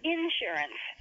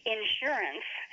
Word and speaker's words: insurance: He said insurance, and I say insurance. How do you pronounce the word? – Insurance. – So you have the emphasis on the second syllable. – Uh-huh. – Well, a lot of people use insurance, the emphasis on the first syllable.